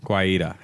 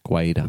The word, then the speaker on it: Guaita: Guaita. How do you say his name? – I don't know. I'm probably saying it wrong. – I think you said it right for a change.